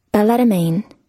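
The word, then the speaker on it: balletomane: Okay, so if you are part of the ballet domain, then you're a ballomain. – Balletomain. – Balletomain? They actually pronounce... – Balletomain. – Wait, one more time? – Balletomain. – Well, they're actually pronouncing the T.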